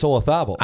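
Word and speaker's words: Olazábal: I don't like the way you pronounce his name. – Well, it's Olazabal. – I know, but I don't care for that. – It looks like Olazabal. – It does. – To Americans.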